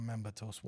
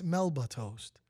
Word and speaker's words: Melba toast: Damn Memba toast. Why you got to say it like that, man? – Because this is what it is. – It's not. It's Melba toast.